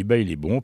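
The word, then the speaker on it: Baile Bom: Alors, Bail et Bon, ça s'écrit B-A-I-L-E, plus loin B-O-M. Mais ça se dit bail est bon parce que là-bas, c'est au Brésil.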